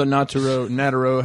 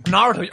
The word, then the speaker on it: Naruto: I'm one of the Nataro. How do you say it? How do you say it? – Naruto.